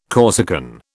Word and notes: Corsican: British English

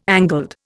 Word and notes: angled: Indian

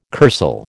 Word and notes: kursaal: American English

